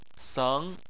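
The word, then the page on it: seng: tone 2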